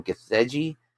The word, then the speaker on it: Gathegi: Uh, e- uh, Edie Githag. Oh, I'm saying that name wrong. Gathegi.